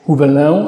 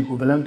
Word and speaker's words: Huvelin: Pisze się Huvelin. Kiedyś mówiłem Huvelin, ale pani jakaś mi zwróciła uwagę, proszę ojca, nie Huvelin, tylko Huvelin. – No to teraz już mówię Huvelin.